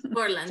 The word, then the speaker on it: Portland: Portland, Estados Unidos, ¿sí se dice así? – Portland, sí.